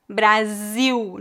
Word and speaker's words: Brasil: O L é pronunciado como U. Então, Brasil, não Brasil, e Portugal, não Portugal. É claro que em final de palavras essa pronúncia é bem fraca, né?